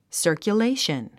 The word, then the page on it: circulation: sə`ː r kjuléiʃən; サァキュレーション